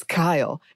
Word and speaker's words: Kylie: And he leaned in and said, actually, it's Kyle. And the E is silent.